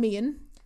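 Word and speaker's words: Meighan: And obviously, I think before even getting into it, we need to talk about Tom Meehan. That is how you pronounce his name, apparently.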